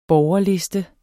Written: borgerliste

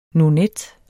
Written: nonet

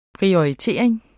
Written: PRIORITERING

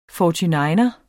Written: 49er

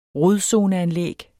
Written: rodzoneanlæg

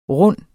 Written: runde

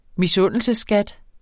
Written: misundelsesskat

